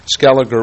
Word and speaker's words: Scaliger: First, from reading some Arabic script in a contemporary portrait of Scaliger, Hugh determined that the scholar's name was pronounced with a hard G rather than the then-accepted soft J. Second, contrary to tradition, Scaliger was not a self-taught recluse but had sought out some of the finest teachers of his time.